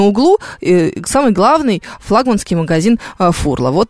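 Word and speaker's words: Furla: Так, шеф-комендор продолжает со мной спорить, значит, по поводу ударения. Я специально, шеф-комендор, итальянскую рекламу, итальянскую послушала. Вот итальянцы в Италии говорят, что она фурла.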